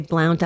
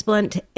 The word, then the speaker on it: Blount: So I'm very familiar with Father James Blount, and I believe it's spelt B-L-O-U-N-T. So some people say Blount. I think it's Blount. That's how he pronounced it.